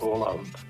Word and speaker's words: Haaland: Mluvil jsem přímo s Norama v Bratislavě na poprvém turnaji a oni mi přímo potvrdili, jak se to jméno vyslovuje, a je to Erling Haaland. – Takže je to, co jsem říkal, a možná i bez toho D, ne, Haaland, jakoby, Haaland. – To úplně nevím, přesně to nechci lhát, ale určitě je to O, je to přehlasované A, to znamená A s kroužkem, holandštině, v norštině. A čte se to jako dlouhé O.